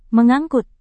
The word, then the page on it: mengangkut: Indonesian